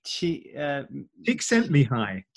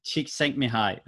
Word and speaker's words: Csikszentmihalyi: Csikszentmihalyi. Csikszentmihalyi, that's it. – Yeah. I mean, if I had to spell it, I'd say C-Z-S-I-S.